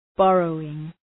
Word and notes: borrowing: ‘bɒrəʋıŋ

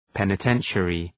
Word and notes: penitentiary: ,penə’tenʃərı

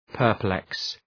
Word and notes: perplex: pər’pleks

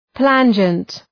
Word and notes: plangent: ‘plændʒənt

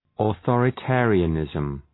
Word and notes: authoritarianism: ə,ɵɔ:rə’teərıə,nızm

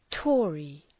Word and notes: Tory: ‘tɔ:rı

